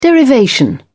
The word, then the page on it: derivation: British English